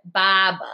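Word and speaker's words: barber: And they spelled it B-A-H-B-A. – Really? – Because we don't pronounce the R. I mean, they don't really spell it like that, but that's how we pronounce it. Baba.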